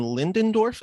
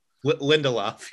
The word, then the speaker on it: Lindelof: I mean, from the Star Wars story that you broke last week, where it was, I believe, is Damon Lindendorf is how you pronounce his name? – Lindelof.